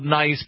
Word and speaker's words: Nice: Yes, I know it's spelled nice, but it's pronounced Nice because, you know, they're French and they talk kind of funny.